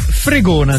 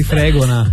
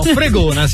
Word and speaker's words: Fregona: Allora, il comune si chiama, ho cercato l'accento prima di parlare, Fregona si chiama. – Bello! – Pensavo magari Fregona. No, Fregona si chiama.